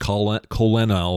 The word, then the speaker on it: colonel: Which is really pronounced colonel or something like that. Colonial or something. – Yeah, there's no R in colonel.